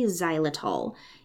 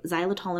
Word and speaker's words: xylitol: If you've ever looked at a sugar-free gum packet, one of the ingredients is usually xylitol. It's spelled X-Y-L-I-T-O-L.